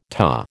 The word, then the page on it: TA: American English